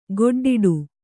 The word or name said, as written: ಗೊಡ್ಡಾಟ